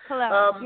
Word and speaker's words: Chlorella: Chlorella, yeah.